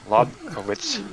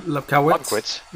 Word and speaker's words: Lobkowitz: That was the case in 1989 when 28-year-old Czech prince named William Lobovitz. Yeah, I'm going to butcher his name. Lobkowitz. – Lobkowitz. – Lobkowitz, yes.